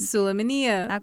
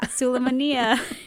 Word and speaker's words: Süleymaniye: I probably can't pronounce them, but Süleymaniye, I think. – Süleymaniye. – Okay, yeah. Süleymaniye.